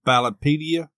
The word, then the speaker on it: Ballotpedia: In a Ballotopedia, how do you say that? Ballotopedia? – Yeah, Ballotpedia.